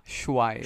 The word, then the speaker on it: Shuaib: Did I say your last name correctly? – You did not, but it's not an uncommon issue. – Yeah. – It's actually Shuaib. – Shuaib.